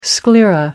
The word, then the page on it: sclera: American English